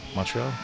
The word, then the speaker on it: Montreal: I'm in San Diego, California, and they are up in Montreal, I believe. That's the right way to say it. Montreal, Quebec. And anyways,